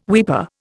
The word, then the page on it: weeper: Indian